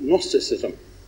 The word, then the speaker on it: Gnosticism: I was recently reading an article about Gnosticism. That's called silent G at the front, if you ever want to look it up.